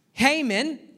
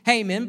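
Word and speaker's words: Heman: Haman, uh, spelled He Man, but it's pronounced Haman, but, anyways, uh, He Man wrote uh, the 88th psalm.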